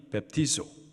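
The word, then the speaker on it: 베프티소 (βαπτίζω): 세례의 헬라식 발음이 베프티소. 여기서 나온 영어 단어들이 baptism, baptist, John the Baptist, 세례를 말하죠. 이 baptizo라는 세례의 본래적인 의미는 물속에 잠그는 것을 말해요.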